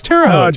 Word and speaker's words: Terre Haute: It was the only town that the Nazis were able to take off the map was Terre Haute, Indiana. And I'm not even saying it right. But when I heard that, I went, wait a minute, that sounds familiar. What is that? No, it's Terre Haute.